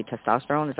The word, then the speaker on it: testosterone: Like, if you guys have any kinds of, you know, I can't even say the word properly, testosterone.